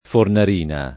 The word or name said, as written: Fornarina